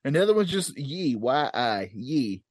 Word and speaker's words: Yi: It is Y-I-Ye. It's not Y-E-Ye. Like ye old, ye old.